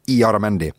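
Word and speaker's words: Illarramendi: Eh, det dere skal huske på, folkens, det er at eh, fotballspilleren Asier Iaramendi uttaler navnet sitt cirka sånn Iaramendi.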